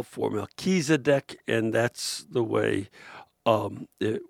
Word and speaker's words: Melchizedek: When I watch the Daily Mass on TV, I hear the name of the priest Melchizedek pronounced with the accent on the second syllable. Knowing that you've studied Middle East languages, would you agree that this pronunciation is correct? Not if you're saying the name in Hebrew, it's not correct. But in English, it is."